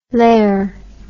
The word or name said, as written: lare